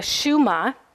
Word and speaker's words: Shema: The Shema, that's how it's pronounced, the Shema, which is the liturgical prayer that Jewish people say.